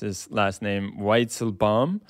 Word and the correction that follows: Weizenbaum: right? – Weizenbaum.